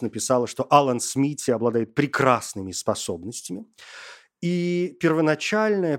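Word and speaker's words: Smithee: Ну, то есть, э, по идее, это произносится как Смити И вот посчитали,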